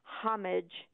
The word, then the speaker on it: homage: Now, homage, the two traditional spellings in both British and American English have always been homage and homage. You can pronounce the H or not as you like, but the accent is on the first syllable.